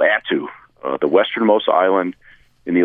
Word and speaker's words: Attu: That's Attu.